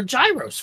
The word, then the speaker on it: gyros: Then I'll go up and play some games, and then we can all meet back here for a gyros feast. That's how we pronounce it, where I'm from.